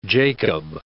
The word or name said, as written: Jacob